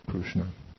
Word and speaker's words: Krishna: Because that's how they pronounce the vocalic R. They pronounce it as Ru. So Krishna.